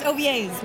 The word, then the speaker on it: Ovies: Am I saying that right? – That's actually really close to right. I'm really impressed. It's Ovies.